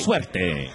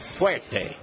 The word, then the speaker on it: Suerte: Suerte. – Suerte.